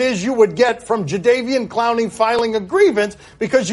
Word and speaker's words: Jadeveon: Jadeveon is how we pronounce that.